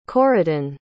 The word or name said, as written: corydon